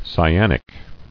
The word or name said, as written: Cyanic